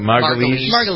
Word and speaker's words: Margulies: Margulies.